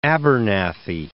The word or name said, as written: Abernathy